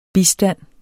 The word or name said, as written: bistand